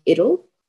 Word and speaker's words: Idil: By the way, I pronounce my name Idil. – Idil. – Well, it's Idil, but that's a bit hard for some people, so I just say Idil. So it's one or the other. I'm a youth worker.